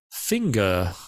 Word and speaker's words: Finger: Gottfried Finger, I guess. In French, it's finger, like your finger on your hand.